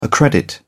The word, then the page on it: accredit: British English